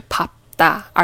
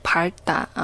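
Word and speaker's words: 밟다: 啊，这边有一个双收音啊，要读后面那一个皮尔的发音，所以是 pop 哒，而不是 par 哒啊。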